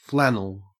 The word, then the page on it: flannel: Australian English